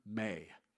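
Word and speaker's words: me (μή): But in the Greek language, if in that rhetorical question it demands a negative answer, it's prefaced with the Greek term me. It's just M and a long A.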